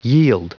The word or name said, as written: yield